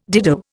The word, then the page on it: dido: Indian